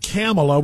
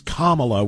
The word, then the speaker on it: Kamala: Yeah, don't forget, by the way, she's not Kamala, which rhymes with capitalist. She's Kamala, which rhymes with communist, just to make sure that that's clear, my brother.